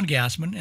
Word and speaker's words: Gassman: I'm John Gassman, and the last name is spelled G-A-S-S-M-A-N.